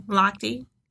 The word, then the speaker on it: Lochte: Lochte.